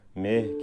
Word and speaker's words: مه: ولی تو این بخش ماه مه که رسیده می به کار برده که می در واقع تلفظ انگلیسی است.